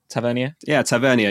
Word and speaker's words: Tavernier: Is that how you say his name? Tavernier. – Yeah, he was the top scorer last game week as well.